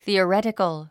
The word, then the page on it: theoretical: θìːərétik ə l; スィオレティカル